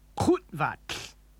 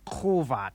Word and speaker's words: kutvat: Kutvat. – Did you hear the sound cut off abruptly in the middle of the word? The correct pronunciation is kutvat, not kuvat.